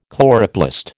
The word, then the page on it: chloroplast: American English